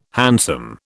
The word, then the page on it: handsome: British English